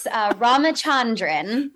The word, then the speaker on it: Ramachandran: Ramachandran.